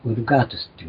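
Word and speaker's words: Vulgatus: ラテン語で V は U なんですね。 V という発音ないわけです。で、ウルガートスっていうのはそういう、意味なんですね。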